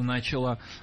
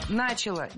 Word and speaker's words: начала: И британские мандаты, и то, как Британия плавно начала отходить от своего статуса глобальной империи. – Начала, неверно. Опять же, переносим ударение на окончание, начала, и живем спокойно.